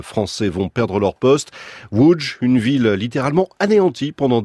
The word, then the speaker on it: Łódź: Ça, c'est pour l'orthographe, mais ça se prononce Łódź, troisième ville du pays, 750 000 habitants.